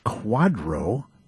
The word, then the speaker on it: QDRO: And this has a special acronym, and people pronounce it QUADRO because it stands for Qualified Domestic Relations Order, a QDRO.